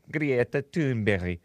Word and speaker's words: Greta Thunberg: Shout out to Samuel from Sweden for correcting my pronunciation. Hopefully I'm doing it justice because that is a Swedish name. Greta Thunberg.